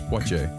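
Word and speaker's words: Poitiers: Ja, eh, ik zou het uitspreken niet op zijn Frans, maar het klinkt als portiers waar ze vandaan komen. – Ik denk portier. – Portier. Uh, volgens, hmm. volgens, mij zelfs poitier. – Ja. Poitier.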